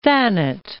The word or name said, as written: Thanet, Isle of